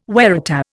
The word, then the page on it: waratah: Indian